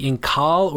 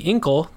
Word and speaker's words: Incal: You have found a book recommendation episode today for the Incal, or the Incal, depending on your pronunciation. It is spelled I-N-C-A-L.